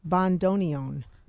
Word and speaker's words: bandoneon: Now I'm going to say this wrong. Bandoneon. Is that you said it perfectly well? – Perfect, right. Yes.